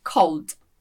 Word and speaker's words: called: A gente não fala called, mas fala called. Called, ok? Como se não tivesse esse ele, called.